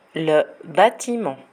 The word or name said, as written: le batiment